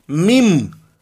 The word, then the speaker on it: meme: y se le ocurre el término mim, que así se pronuncia en inglés, como si sonara M-I-M, mim, porque dice, tenemos los genes, jean o jeans y tenemos los memes, memes.